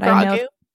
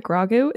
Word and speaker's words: Grogu: Do you pronounce it like that? Grogu? Is that how you pronounce it? I thought it was Grogu. – Oh, I'm probably wrong. – I like to say Grogu. – Grogu, that's cute. I like it.